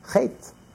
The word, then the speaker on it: Chet: The word Chet, the Baal Shem Tov said, Chet is spelled out. Chet is the Hebrew for sin. Is Ches, Tes, Aleph. Aleph is hidden. You don't pronounce the Aleph. Chet. The Aleph is written, but it's not pronounced.